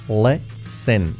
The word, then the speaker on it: listen: Fijaos la pronunciación. La L. La I como una E. Listen. Y la T no se pronuncia.